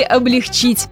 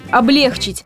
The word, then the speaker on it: облегчить: Правильно, если подумали «облегчить», поскольку ударение на второй слог, то есть «облегчить», это грубейшая ошибка и литературной нормой не является.